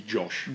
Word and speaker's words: Jawsh: It's meant to be Josh. – Josh. – Yeah, it's spelt J-A-W-S-H, but I reckon that's meant to Josh.